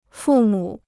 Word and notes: 父母: fù mǔ

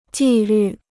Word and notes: 纪律: jì lǜ

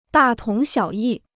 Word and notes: 大同小异: dà tóng xiǎo yì

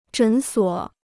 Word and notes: 诊所: zhěn suǒ

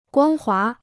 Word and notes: 光滑: guāng hua

